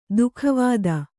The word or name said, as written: ದುಃಖವಾದ